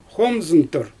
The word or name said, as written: xoomzɯntɯr